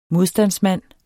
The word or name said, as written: modstandsmand